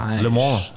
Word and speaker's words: Le Mans: Le Mans, Le Mans, Le Mans FC.